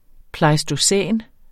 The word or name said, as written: pleistocæn